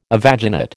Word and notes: evaginate: American English

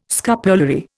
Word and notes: scapulary: Indian

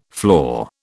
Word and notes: floor: British English

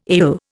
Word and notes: ale: Indian

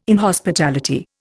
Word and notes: inhospitality: Indian

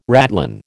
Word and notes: ratline: American English

